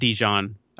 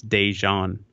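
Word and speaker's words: DeJohn: It's not Dejean. It's spelled D-E-J-O-H-N. A lot of people want to say like Dijon mustard, but it's Dejean or Dejean.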